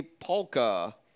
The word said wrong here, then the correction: Pakula: Did I say his name right? – Pollock. – Pollock. What? – What? – Wasn't that Pollock? – Pakula. No. – Pakula. Yeah, Pakula.